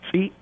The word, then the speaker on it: tsi7: We have tsit. That's a deer meat. – How did you say that again? – Tsit, spelled T-S-I-7.